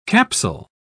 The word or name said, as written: capsule